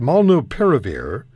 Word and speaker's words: molnupiravir: Is it molnupiravir? – You got it.